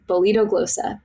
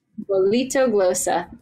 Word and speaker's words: Bolitoglossa: It's Bolitoglossa. B-O-L-I-T. Oh, gosh. I guess I can't spell it. B-O-L-I-T-T-O-G-L-O-S-S-A, maybe? Bolitoglossa. Yep. – Tenors, can you spell it? B-O-L-I-T-O-G-L-O-S-S-A.